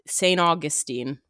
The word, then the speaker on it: St. Augustine: I do have to say that in case we get any listeners, St. Augustine is how it's pronounced.